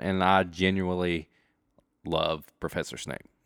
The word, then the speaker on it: Snape: Snape? – Yes. – Snape.